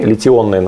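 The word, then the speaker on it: литий-ионные: Это литионовые батареи. Литионные, на самом деле, правильно говорить.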